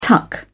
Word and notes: tuck: British English

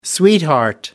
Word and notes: sweetheart: American English

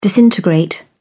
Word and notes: disintegrate: British English

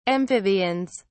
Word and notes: amphibians: British English

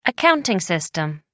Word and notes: accounting system: American English

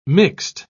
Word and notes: mixt: British English

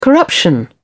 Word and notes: corruption: British English

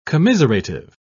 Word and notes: commiserative: British English

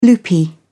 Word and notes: loopy: British English

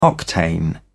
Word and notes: octane number: British English

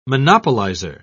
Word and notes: monopolizer: British English

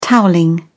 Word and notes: towelling: British English

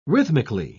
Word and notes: rhythmically: British English

